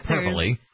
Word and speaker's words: hyperbole: Hyperbole. – Hyperbole, I believe. Is that an alternative fact? – No, no. – Actually how it's pronounced.